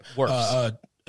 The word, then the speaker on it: Wirfs: I always mispronounce his last name. – Wirfs. – What? – Wirfs. – Wirfs, that's it.